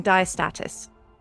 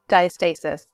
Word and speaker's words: diastasis: and diastasis. So welcome. I feel like I need to ask you, did I pronounce the last one correctly? – Diastasis. Although in Europe, diastasis, both ways.